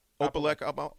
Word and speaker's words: Opelika: I'm in Opelika. Am I saying that right? – Yeah. – Opelika, Alabama.